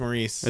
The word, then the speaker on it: Maurice: Is it Morris or Maurice? – Maurice. I think it's Maurice. – It's spelled Maurice, but every time I see that, I don't want to say Maurice. I want to say Morris still.